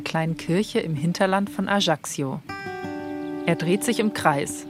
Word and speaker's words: Ajaccio: Jetzt mit Verena Meyer in die Berge von, nun kommt es, ein schwieriges, aber genauso schönes Wort auch, Ajaccio. Verena, einigermaßen richtig ausgesprochen? – Ja, war richtig. Ajaccio, sagen Sie. Ich habe es auch beim ersten Mal komplett falsch ausgesprochen.